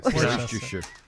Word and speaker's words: Worcestershire: I'm sick of the fact that you said Worcester sauce. – Worcester. – Worcester sauce. – Worcestershire.